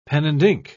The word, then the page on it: pen-and-ink: British English